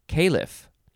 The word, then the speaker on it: caliph: Califal, you'd probably just pronounce it califal, is an adjective, and I actually, there's another pronunciation, it could be caliph. So there's caliph and caliph. I think both are good.